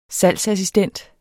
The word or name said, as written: SALGSASSISTENTER